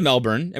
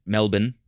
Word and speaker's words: Melbourne: Well, we went to Melbourne and we lived there.